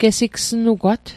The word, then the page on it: gesigsnugwat: Mi'gmaq